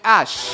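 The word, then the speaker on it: æ: Ah, espérate, eh, el A y la E se pronuncian ash.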